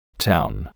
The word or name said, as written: town